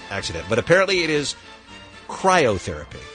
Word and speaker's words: cryotherapy: cryotherapy tank? – Not even. – Nope. – I think it's just cryo. Cryotherapy, and I said chirotherapy.